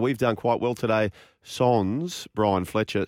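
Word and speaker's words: sans: Is that how you say it? Sons? – Sons, yeah. Avec is with and Sons is without.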